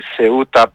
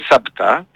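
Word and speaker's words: Ceuta: Η Μλίλα, Μελίλα τη λένε στα ελληνικά, και Σεούτα που τη λένε Σάπτα στα αραβικά.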